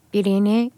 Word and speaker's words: Irene: Will you play Irene? Is that how you say it? – Yeah. – Irene. – Yeah. – But it's the name Irene in Portuguese, basically.